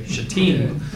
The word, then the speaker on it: Shittim: But while Israel was staying at Shittim, that's the way you pronounce it, by the way, Shittim.